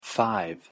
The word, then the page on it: five: English